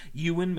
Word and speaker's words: Ewan: The film stars Ewan, is that how you pronounce that? – Yeah. – Ewan McGregor, Albert Finney, Helena Bonham, Billy Crudup, and Matthew McGrory.